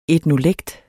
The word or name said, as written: etnolekt